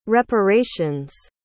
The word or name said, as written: reparation's